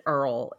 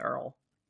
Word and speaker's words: Earle: And I'm pretty sure that you pronounce it Earl. It didn't tell me any differently, but it has an E on the end, so it was throwing me off. But we're going with Earl.